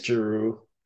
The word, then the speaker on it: Giroux: I think it's Giroux. Yeah, just because I used to say something else, and then I was shocked when I heard it pronounced this way.